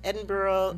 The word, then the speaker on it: Edinburgh: Because you, before the pandemic, you had gone to Scotland, Edinburgh.